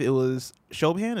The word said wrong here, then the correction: Siobhan: is that how you pronounce her name? – Oh, Siobhan? – Siobhan? – Yeah. It's Siobhan.